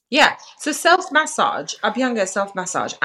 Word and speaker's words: Abhyanga: So in terms of Ayurvedic rituals, I was going to ask you about Abhyanga. Have I pronounced that correctly? – Yeah, Abhyanga masa.